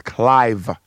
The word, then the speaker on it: Clive: But my name is Clive, C-L-I-V-E, like Clive Davis, who gave Whitney Houston, you know, her big break.